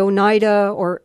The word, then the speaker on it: Oneida: And it became a silverware company called the Oneida, that's how it's pronounced, Oneida Silverware.